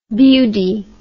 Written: bewdy

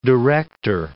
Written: director

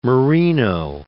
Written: merino

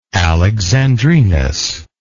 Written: Alexandrinus

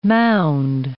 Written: mound

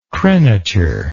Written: crenature

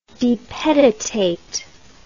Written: depeditate

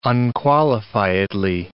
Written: unqualifiedly

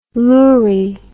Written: lourie

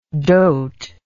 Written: doter

